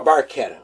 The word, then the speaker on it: barchetta: Bar- bar- barchetta, I'm sorry, I just learned how to pronounce that.